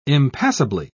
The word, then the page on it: impassably: British English